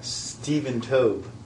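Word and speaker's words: Stephen Toub: Stephen Tobe. That's how his name is pronounced.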